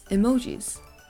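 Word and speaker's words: emoji (絵文字): The last one we have here is emojis. So this word is originally from Japan because Japan was the one who invented emojis. The pronunciation in Japanese is emoji.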